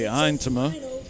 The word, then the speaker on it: Yntema: And in case you're curious about that last name, it is unusual. It's spelled Y-N-T-E-M-A. Eintema is the last name.